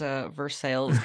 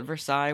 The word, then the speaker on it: Versailles: I called it Versailles once, and a Connecticut native made fun of me. – That'll teach you to say things correctly. – I do think I probably didn't pronounce it quite right, because I feel like it really needs a lot more, like, emphasis on the A-H, if I had any of my Maine accent anymore, which I think...